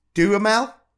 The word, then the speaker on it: Duhamel: And it was Jennifer Lopez and Josh, what I say his name right? Josh Duhamel? – Yeah.